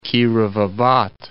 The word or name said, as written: Kirovabad